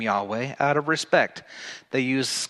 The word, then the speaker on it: Yahweh: Another word that you find for God is one that we pronounced Yahweh. Yahweh. Y-A-W-A-H.